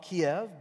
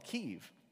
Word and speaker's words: Kyiv: They used to call it Kiev, but They're rejecting that Russian pronunciation, and it's called Kyiv.